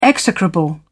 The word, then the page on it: execrable: British English